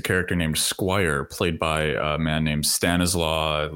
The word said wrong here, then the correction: Stanislav: last name I can't pronounce. – Stanislav. – I'm just going to cut that clip in every time I have to say Stanislaw. – Stanislav. – So I can have the right pronunciation.